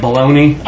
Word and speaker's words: bologna: because B-O-L-O-G-N-A does not sound like how you would spell baloney. Okay, it's not even close. It's Bologna. But it's because of this song. – I like that. – My baloney has a first name. It's O-S-C-A-R.